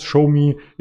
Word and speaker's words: Xiaomi: Und eine Meldung dazu hat den Markt beflügelt, insbesondere den asiatischen Markt, die Xiaomi, die Xiaomi hieß die Aktie oder spricht man sie aus. ShowMe ist wieder über die 3-Euro-Marke geklettert.